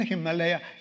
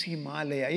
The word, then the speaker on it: Himalaya: But if you want to pronounce it right, it is not Himalaya, it is Himalaya.